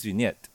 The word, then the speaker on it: Vignette: Vignette Valencia. This name is going to trip me over because it has a G, but you don't say the G. What? – What? – Her name is Vignette. And when you spell it, it's V-I-G-N-E-T-T-E.